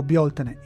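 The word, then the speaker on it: Bealtaine: Beltane, in pronuncia anglicizzata, o Bioltene in irlandese moderno, che significa maggio, è la festa del fuoco luminoso, come ci ricorda l'etimologia del nome.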